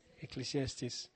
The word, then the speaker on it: Ecclesiastes: Ecclesiastes.